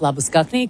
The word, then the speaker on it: Labuschagne: I mean, I think over there we start calling him Marnus Labuschagne because that's actually how you pronounce it when he gets back to South Africa.